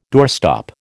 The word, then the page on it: doorstop: American English